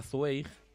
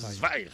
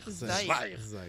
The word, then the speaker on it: Zweig: Bueno, pues decía que yo hablé de él una vez y le llamaba Zweig. Sí. Y una alemana me corrigió, no, es Zweig. – Zweig.